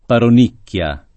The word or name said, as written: paronichia